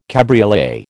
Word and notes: cabriolet: American English

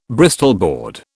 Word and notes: Bristol board: British English

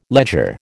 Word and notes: ledger: American English

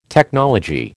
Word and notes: technology: American English

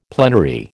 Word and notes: plenary: American English

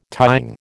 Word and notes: tying: American English

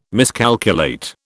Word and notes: miscalculate: British English

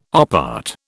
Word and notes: op art: British English

